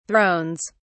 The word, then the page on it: thrones: British English